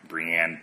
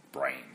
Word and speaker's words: Brienne: I know in the show they pronounce her name Brienne, but in the books, when it's the audiobooks is read by Roy Dotrice, he pronounces it Brain.